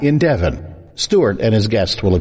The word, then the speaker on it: Devon: Devon. However, it's spelled D-E-V-O-N, so you might say Devon, like they do in Chicago, but here it's Devon. Now, there was a sports car named Devon, spelled D-E-V-I-N, and it was made in California.